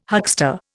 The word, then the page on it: huckster: Indian